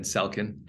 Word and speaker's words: Selkin: M-Y-K-E-E. And then Selkin, S-E-L-K-I-N.